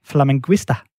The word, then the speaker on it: flamenguista: Du er glødende flamingo-fan, øh, en såkaldt flamenguista. Er det rigtigt udtalt? – Det er korrekt, og jeg kan også øh, prale med at være det eneste danske medlem af klubben.